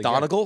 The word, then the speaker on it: Donegal: Yeah, Donegal, but everyone pronounces it Donegal, well, like here, a monocle.